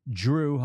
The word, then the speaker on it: Jrue: And you say his name, you try to say his name as clearly as possible, and it still sounds like Jrue with a D. It's Jrue Holiday, which I love.